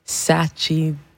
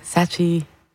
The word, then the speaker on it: Sachidananda: And music from Alice Coltrane, Journey in Sachidananda. That's how you say it. Sachidananda. Sachidananda.